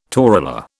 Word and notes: torula: British English

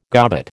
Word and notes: gobbet: American English

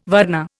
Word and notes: varna: Indian

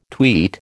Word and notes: tweet: American English

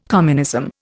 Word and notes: communism: Indian

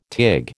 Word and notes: tig: American English

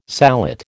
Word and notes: sallet: American English

